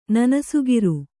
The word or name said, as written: ನನಸುಗಿರು